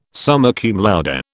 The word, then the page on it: summa cum laude: British English